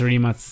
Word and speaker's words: Rimac: Also because Matte Rimac, or Rimac, I think that is the correct pronunciation in Croatian. – Yeah, it's Rimac.